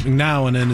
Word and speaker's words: McNown: McNown. – You know? – I always say, because it's M-C-N-O-W-N. McNown. – Yes. So that is how I pronounce it and have pronounced it for my whole life.